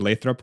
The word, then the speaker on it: Lathrop: By the way, kudos for pronouncing Lathrop correctly. I've heard so many people calling it something else, and I'm like, it's Lathrop.